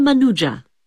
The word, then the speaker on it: Manuja: My name is Samira Manuja. – Can you spell that, please? – M-A-N-U-J-A.